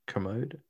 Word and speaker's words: Kermode: K-E-R-M-O-D-E. Kermode. Oh, no, it's Kermode. Okay. – Kermode? – Yeah. Yeah, it is now.